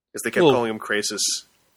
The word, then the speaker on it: Crassus: As Crassus, spelled Crassus, but pronounced Crasis, from Spartacus Blood and Sand.